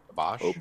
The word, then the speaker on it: Obosh: So I entered with um, Mono Red Obish, Obush. How do you say mm-hmm. his name? Obosh. – Obosh. Obosh.